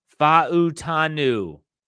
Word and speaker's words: Fa'utanu: That's how you say his name, according to the NFL draft documentation that's out there. It's not Fa'utanu. It's Fa'utanu. A lot of times those Polynesian names like Palomalu, they pronounce every single Fa'utanu.